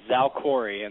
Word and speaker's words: Xalkori: And is it Xalkori? How do you say that drug? – The trade name is Xalkori, and the generic, I'm sure you'll probably hear from Dr. Pinnell later, is Crizotinib.